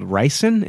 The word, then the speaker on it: ricin: But there's a poisonous toxin called ricin. Is it ricin? – Yeah.